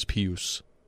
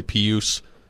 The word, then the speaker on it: Pius: And I believe that's how you say it, is Pius. I said Pius for a long time. – I like Pius better. I like Pius better, too. But I think it's Pius. Technically Pius Suter, who had a great year last year for the Blackhawks as a rookie, and they let him go.